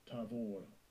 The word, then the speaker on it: Tavor: Strong's H8396, Tavor, Tavor. – Is defined in the African Hebrew language as a mound, M-O-U-N-D.